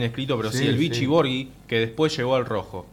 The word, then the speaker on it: Borghi: Además del pelado Grillo, en el Milan jugó Claudio Boggi, que después llegó al rojo. No sé si se pronuncia Boggi o Boggi. – Claudio Borghi.